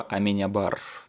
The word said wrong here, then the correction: Аменабар: Мне кажется, ударение ты все равно продолжаешь ставить не туда. Алехандро Аминябар, нет? Нет, у него не на последнюю, а на предпоследнюю А ударение. Аменабар?